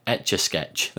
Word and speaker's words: Etch A Sketch: It, I've, I've seen people describing it as it looks like it was done on on an esca, how do you say that etch a sketch. There you go.